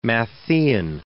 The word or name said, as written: Matthaean